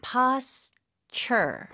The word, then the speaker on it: posture: Posture. – Posture. – 네, 중간에 T 발음을 약간 CH 발음으로 한번 해주세요. Posture.